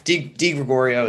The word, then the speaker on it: DeGregorio: Did I pronounce that right? – Yeah, close enough. DeGregorio is good.